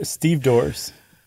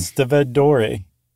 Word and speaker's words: stevedore: That's what they call themselves in The Wire, Steve Dorrs. – It's been a long time since I saw that season. – That's where I learned how to pronounce it correctly, actually. – Instead of Stevedore.